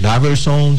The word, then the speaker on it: Daverson: Que é Daverson MS. Então, o pessoal pode nos seguir, acompanhar nosso trabalho. – Daverson com V, né? Normal? – Com V. Daverson da maneira que se pronuncia.